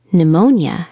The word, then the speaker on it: Pneumonia: Pneumonia has a silent P.